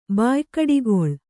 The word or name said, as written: ಬಾಯ್ಕಡಿಗೊಳ್